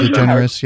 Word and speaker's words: DeGeneres: I don't know how you pronounce her second name. Is it DeGeneres or Ellen DeGeneres? Yeah.